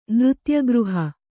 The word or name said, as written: ನೃತ್ಯಗೃಹ